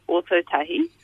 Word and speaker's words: Ōtautahi: Um, so Ōtautahi, uh-huh.